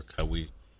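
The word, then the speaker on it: Kawhi: Also, 8:30 is our reporter's roundtable where we break down where Cowie Leonard is going to end up. – Cowie. – Oh, okay. Oh, am I saying it wrong? – No, that's fine.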